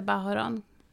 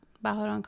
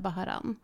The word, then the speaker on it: Bahoron: Om jag är med mina gotlandskompisar till exempel så säger jag Bahoron. För att det är så jag har liksom blivit kallad där.